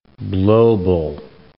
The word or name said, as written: Blobel